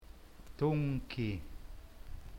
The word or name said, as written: tungki